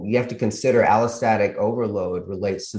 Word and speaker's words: allostatic overload: Yeah, allostatic overload.